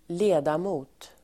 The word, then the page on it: ledamot: [²l'e:damo:t]